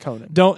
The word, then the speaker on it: Conan: He was on Conan. – Yeah. – Or Conan. I'm not sure how he pronounced it. – Conan. – It's Conan. Don't take advice from people like me who got very lucky.